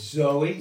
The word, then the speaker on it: zoe: Okay, it's Zoe, Z O E, long E.